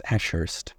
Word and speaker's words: Ashurst: Is it Ashurst? – That's right. – I said it right? – Pronounce the H twice, Ashurst.